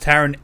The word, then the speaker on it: Taron: I heard his name is pronounced Taron Edgerton.